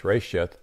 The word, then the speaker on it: reshiyth: And the phrase in the beginning there came from one single word, which was, it's pronounced Resheth, R-E-S-H-I-Y-T-H, Resheth.